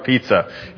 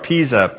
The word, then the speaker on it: Pisa: It's not the Leaning Tower of Pizza. It is the Leaning Tower of Pisa. P-I-S-A is how it's pronounced.